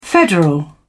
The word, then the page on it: federal: British English